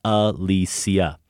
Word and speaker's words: Alicia: If you want to know the Swedish pronunciation to Alicia Vikander, the C in Alicia is pronounced S, so it's Alicia.